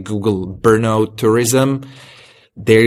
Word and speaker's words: Brno: And can you help me to pronounce this one name in the Czech Republic? Brno? How's it pronounced? – Brno. it's BRNO. It's actually just a 4-letter city.